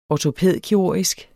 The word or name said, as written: ortopædkirurgisk